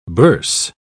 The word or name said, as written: bourse